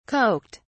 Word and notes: coked: British English

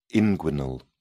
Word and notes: inguinal: British English

